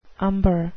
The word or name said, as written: umber